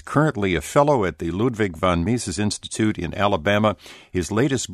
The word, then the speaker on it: Ludwig von Mises: My pleasure, and I'm happy to say you're the first radio host who's ever correctly pronounced Ludwig von Mises Institute, so thank you very much.